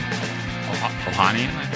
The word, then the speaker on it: Ohanian: Ohanian. – Ohanian.